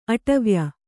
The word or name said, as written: ಅಡವಾಯ್